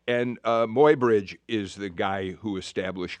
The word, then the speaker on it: Muybridge: Why in your book, is it Muybridge? Is that how you pronounce it? – Muybridge.